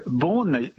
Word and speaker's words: Beaune: Beaune, how do you say it? More difficult. – How do you say it then? – Well, Beaune, you said it very well.